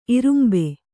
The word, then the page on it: ಇರುಂಬೆ: irumbe